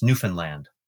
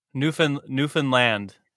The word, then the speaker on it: Newfoundland: And I have to tell you, that's how it's pronounced, Newfoundland. – Newfoundland. – Yeah, like understand Newfoundland. Oh, you get in big trouble if you say it wrong, rightfully so, but it's often mispronounced.